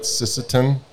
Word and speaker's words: Sisseton: So you guys farm out in, is it, I'm going to say it wrong probably, but Sisseton? – No, you said it right.